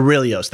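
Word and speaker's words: Orrelios: Is that how we pronounce Aurelios. – His last name? Aurelios, thank you.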